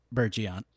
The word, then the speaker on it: Bergeon: Bergeon. – Okay. – You said it right? – I think we are also pronouncing it Bergeon. – Bergeon. Yeah, no, I think it's called Bergeon, but so it's branded Camille Fournet, so that's like my fancy tool.